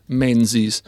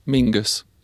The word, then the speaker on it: Menzies: Esatto, e quindi per esempio c'è il primo ministro australiano molto conosciuto degli anni 50-60, che in Australia si chiama sempre Menzies. Però questa è la pronuncia australiana, la pronuncia scozzese, perché il cognome d'origine scozzese è sempre Mingus.